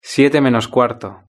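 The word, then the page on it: siete menos cuarto: Spanish (Spain)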